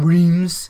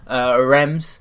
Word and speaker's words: Reims: That's how you pronounce it, by the way, not Reims. Rems. A bit like Ren, but with an M.